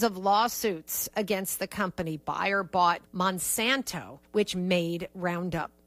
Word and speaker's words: Bayer: It's a German company. They're soaring. We pronounce it Bayer here.